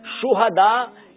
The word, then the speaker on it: شہدا: شہدا یہ صحیح لفظ ہے, شہید کی جمع.